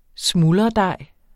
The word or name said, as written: smuldredej